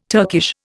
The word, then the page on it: Turkish: Indian